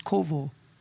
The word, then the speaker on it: qavah: But they that wait, this word wait is a Hebrew word, kovo. It's spelled Q-A-V-A-H, but it's pronounced kovo.